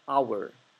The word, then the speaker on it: our: Y recuerden que our puede pronunciarse también our.